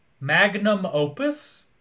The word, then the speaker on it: magnum opus: I mean, Dan, that's their Mangus Opum. Oh, Dan, where are you? – They're Mangus Opum? – Mangus? How do you say it? – Magnum Opus? – Oh, my God.